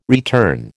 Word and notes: return: American English

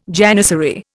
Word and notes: janissary: Indian

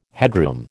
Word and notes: headroom: American English

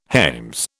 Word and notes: hames: British English

